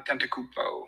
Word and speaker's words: Antetokounmpo: I'm Antetokounmpo.